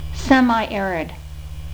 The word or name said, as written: semiarid